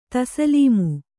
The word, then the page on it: ತಸ್ಲೀಮು: taslīmu